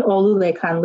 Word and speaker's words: Olulekan: Your friend, whose name is spelled O-L-U-L-E-K-A-N and is pronounced Olulekan, continually is called Olu.